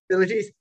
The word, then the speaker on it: Philites: In 950 CE, the Necronomicon was translated into Greek and given the title Necronomicon by Theodoros Philites. I believe I'm saying that correctly, because it's either Philites or Philates, like the satyr from Hercules. – Philites.